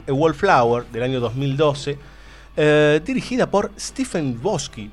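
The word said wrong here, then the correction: Chbosky: puede ser, está bien pronunciado muchachos porque tiene un Shbosky, Shbosky, como lo pronuncias vos, es como lo vamos a pronunciar nosotros durante el bloque. Si nos sale. Bueno, Stephen Shbosky.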